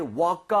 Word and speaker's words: woke: 그리고요, 주의하셔야 될 게 이 과거형 발음할 때요, walk 하지 마세요. – 어? – 그거는 걷다잖아요. – 아, walk. – W-A-L-K, right? Walk. So, I woke up 이렇게 하지 마시고요. I woke up. 거의 오에 가깝게 발음.